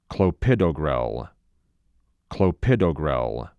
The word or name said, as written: Clopidogrel